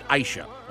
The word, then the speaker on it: Ayse: It's spelled A-Y-S-E, but she pronounced it Aisha, okay?